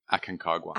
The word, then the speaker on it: Aconcagua: Aconcagua. – Aconcagua one.